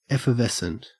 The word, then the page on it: effervescent: Australian English